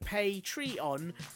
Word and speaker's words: Patreon: that I've been pronouncing Patreon wrongly for ages because I thought it was Patreon, like it was owned by someone called Pat, or you know, like the UK pronunciation of patriotic. But actually, it's like the US version of pay tree on uh, those trees you can deposit cash into that they have in the Americas.